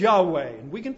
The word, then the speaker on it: Yahweh: Now, when the consonants are added, the word is very easy to pronounce. YAHWEH is Yahweh.